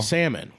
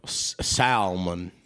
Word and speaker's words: salmon: Salmon. Why is there an L? What are we doing with that thing? – I know. – Toss that shit. – A lot of people like to pronounce that L, too.